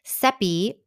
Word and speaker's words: Seppi: I'm your host, Seppi Rhymes with Peppy.